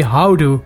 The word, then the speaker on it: houdoe: We say it here in the South. We have a special way. We say houdoe.